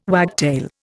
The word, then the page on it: wagtail: Indian